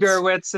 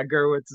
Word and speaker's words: Gerwitz: It's Gerwitz. It's fine. I mean, okay. You know, it's, yeah, Gerwitz is how I pronounce it, but it is, yeah, the spelling's a little bit strange.